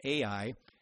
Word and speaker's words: Ai: And the result of the sin was that they lost, after they had this great victory over Jericho, they lost to a little town called Ai, capital A, small letter I, we just pronounce it sometimes Ai, a very small village, small town, but they lost in a battle.